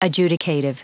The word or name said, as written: adjudicative